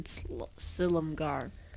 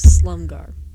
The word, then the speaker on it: Silumgar: Dragon Lord Silumgar. I mispronounced that earlier as Slumgar.